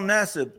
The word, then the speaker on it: Nassib: Um, Carl Nassib.